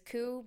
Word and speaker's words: Koo: Like, my last name is Koo, but I was almost a Goo, because in Korea it's pronounced Goo. coup, goo, coup. So who knows?